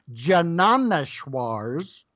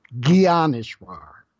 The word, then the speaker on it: Gyaneshwar: You could also pronounce it Jnaneshwar's, but the proper pronunciation is like a letter G, Gyaneshwar.